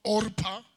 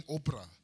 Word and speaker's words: Orpah: They married Moabite women, one named Orpah. Most of the time I pronounce his name Oprah.